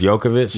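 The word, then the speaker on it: Jokić: It's Jokic? – Yeah.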